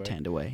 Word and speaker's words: Thandiwe: I think you pronounce it Tandaway. Yeah.